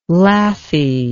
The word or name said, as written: lathy